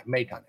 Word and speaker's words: Maton: Maton. M-A-Y-T-A-H-N is the pronunciation guide.